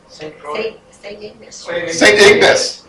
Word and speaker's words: Ignace: St. Ignace. – Yeah. Because you pronounce it Frenchly, right? Because they say Ignace?